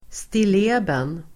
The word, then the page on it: stilleben: [stil'e:ben]